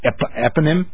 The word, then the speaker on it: eponym: Not only was I a stutterer, but I was also fat, so Porky Pig was a frequent eponym. Is that how you say it? – Sure. – I'm silly. How do you say it, Sam? Tell me. – No, that sounds good.